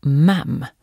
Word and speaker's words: Ma'am: Och Ma'am uttalas med kort A som i engelskan för sylt, jam.